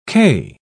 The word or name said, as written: cay